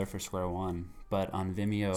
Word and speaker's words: Vimeo: Okay, so I know that when we first started speaking, you sent me a video of, Vimeo.